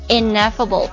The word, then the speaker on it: ineffable: 先来看第一个单词，它读作 ineffable，有一个连读在里面的。